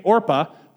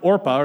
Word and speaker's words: Orpah: If you listen to anything of Oprah as she talks about it, she was named Orpah, but apparently where she grew up, nobody could pronounce Orpah, or they just kept messing it up, so they changed it to Oprah.